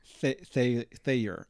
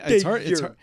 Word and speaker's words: Thayer: I've been giving it two syllables and you've been giving it one. So I've been saying Thayer, like layer cake. And you say Thayer. – Yeah. – Like walking on air.